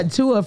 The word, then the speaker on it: Tua: Tua, is that how you say his name? – Yeah, Tua from Miami, the quarterback.